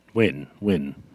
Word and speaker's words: Nguyen: His name is Hart Nguyen. – Win. – I don't know. Yesterday? Today? – No. That's how you pronounce that name.